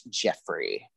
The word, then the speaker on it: Geoffrey: It's G-E-O-F-F-R-E-Y. – Jeffrey Paul? I think it's only Joffrey when it's in Game of Thrones. Otherwise, it's pronounced Jeffrey.